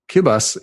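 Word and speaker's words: Kiribati: Kibas, it's not pronounced anything like it looks. – Oh, yeah. – On paper, the R sounds a little bit like an L, and the T and the I is pronounced as an S, which is weird historical reasons for that.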